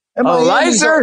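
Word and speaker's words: Elieser: But I'm not betting with Eliezer Hernandez or however you pronounce his first name. – Elizer Hernandez. – Oh, we're going Elizer. Okay, Elizer. I, you know what? That makes sense based off the way that it's spelled out there.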